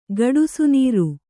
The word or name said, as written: ಗಡುಸುನೀರು